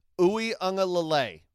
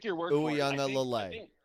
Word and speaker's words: Uiagalelei: So get used to the name, DJ Uiagalelei. I nailed it. Couldn't do it any better. DJ Uiagalelei. – I think gun to my head, I would have said like Uiagalelei.